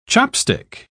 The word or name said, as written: chopstick